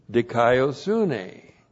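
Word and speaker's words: dikaiosune (δικαιοσύνη): Looks like this, D-I-K-A-I-O, long O. Dikaiō means to declare one to be righteous. Now, there is a noun that comes from this, dikaiosune. Looks like that in the Greek, D-I-K-A-I-O-S-U-N, long E. Dikaiosune is righteousness. That's the noun, righteousness. There's an adjective, dikaios, D-I-K-A-I-O-S, and that's the adjective, righteous.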